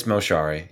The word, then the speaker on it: Moshari: Yeah, it's Moshari. Yeah. – Moshari. – Moshari.